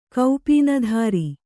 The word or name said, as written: ಕೌಪೀನಧಾರಿ